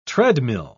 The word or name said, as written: treadmill